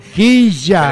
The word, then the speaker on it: Quilla: Quilla. – Pero pronuncia así, claro. – Ahora sí. – No, porque la doble claro. L se pronuncia ya.